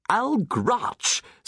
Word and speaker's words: Algrach: Algrai, algrew, algrow, algree, algrumble, algrumple, Algrach, said Dr. Drake. You have to roll your R's, Daniel, like a dragon, like...